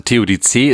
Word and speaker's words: Theodizee: also Theodizee ist tatsächlich ein französisches Wort, ne? So, wird genauso ausgesprochen und ist dann halt die Frankophonierung eines Griechischen, heißt das so? – Keine Ahnung, ist auch egal.